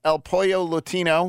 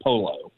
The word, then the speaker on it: El Pollo Latino: I sometimes miss on that myself, but that's the ya sound. Not it's pollo, not polo.